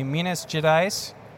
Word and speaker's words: Minas Gerais: I just learned how to say that properly. Minas Gerais. Did I sound right? – Yes.